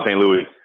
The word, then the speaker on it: St. Louis: Todd St. Louis. Like the city, but pronounced different.